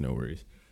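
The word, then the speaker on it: NxWorries: And it's like NX Worries, but, you know, pronounced No Worries.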